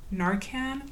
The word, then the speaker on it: Narcan: Narcan? – Yeah. – Sorry, I don't know if I'm pronouncing it right. – Yeah, that's right.